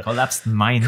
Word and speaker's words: Collapsed Minds: Wir sind im Soundcafé und noch dabei sind natürlich wir, Entoria, Needle, wovon der Eddie involviert ist und natürlich Collapsed, Collapsed Mind. Ich finde, ich habe das schon mal ausgesprochen und es ist ein richtiger Zungenbrecher. Collapsed Minds.